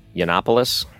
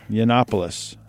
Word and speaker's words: Yiannopoulos: How do you say his last name? – Yiannopoulos. – Yiannopoulos.